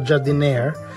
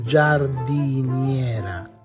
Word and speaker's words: giardiniera: That for sure is not jardiniere, but it's giardiniera.